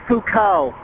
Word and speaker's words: Foucault: It's actually pronounced Foucault, as in Foucault's pendulum. Yes, I know how it's spelled, but, you know, it's French.